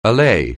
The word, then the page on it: allay: English